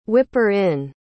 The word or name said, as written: whipper-in